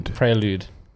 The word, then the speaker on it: Prelude: Prelude. – Like interlude, but a prayer.